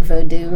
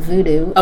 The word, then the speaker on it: Vodou: A lot gets left out, but but to just give you a little tidbit about it, Vodou, and I pronounce it Vodou rather than Vodou. – Okay. – And it's spelled differently. – I noticed that. – The V-O-O-D-O-O refers to the sort of tourist Hollywood image of spellcraft and people sticking pins and dolls and all of that.